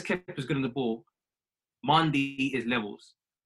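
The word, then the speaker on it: Mendy: I've taken the time to learn the correct pronunciation of my goalkeeper. It's Mandi. Because he's Mandi. French, right? – Oh, Mandi. – So man wow. like Mandi, yeah?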